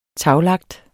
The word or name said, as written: taglagt